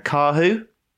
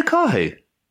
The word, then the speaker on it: Karhu: K A R H U. Have I said that right? – All right. Yeah.